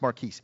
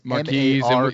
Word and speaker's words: Marquez: No, it's spelled Marquez, right? – Marquez, yeah. – But it's pronounced Marquise. – Marquise.